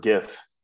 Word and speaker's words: GIF: I pronounce it GIF. – GIF with a hard G. – Yeah. Like gift with no T.